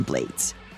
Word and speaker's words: Blades: And the album reached the ears of the iconic Panamanian musician Ruben Blades, which, yes, is how he pronounces his last name.